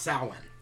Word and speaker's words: Samhain: It's pronounced Samhain.